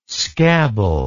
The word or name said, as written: scabble